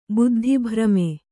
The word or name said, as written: ಬುದ್ಧಿಭ್ರಮೆ